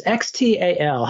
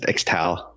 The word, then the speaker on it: Xtal: This is Aphex Twin, and it's X T A L. How do you pronounce that? I ah, just Xtal. Xtal.